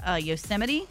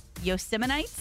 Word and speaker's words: Yosemite: But yesterday, President Donald Trump mispronounced Yosemite. – Uh-huh. – Called it Yosemites.